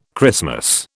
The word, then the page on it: Christmas: British English